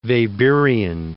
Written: Weberian

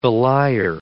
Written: belier

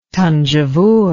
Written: thanjavur

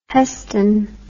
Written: heston

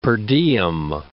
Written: per diem

